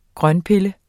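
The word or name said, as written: GRØNTPILLE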